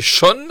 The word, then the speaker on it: Sean: On ne dit pas Sean, mais Sean.